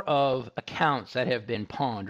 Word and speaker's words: Pwned: It's called Have I Been Pwned? And leave out the A in pawn. It's P-W-N-E-D.